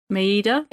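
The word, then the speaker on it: Mayeda: Yeah, so this film, like we said, was directed by Paul Maeda. Is that how you say his name? Maeda? – Maeda, but yeah.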